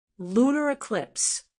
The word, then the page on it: lunar eclipse: American English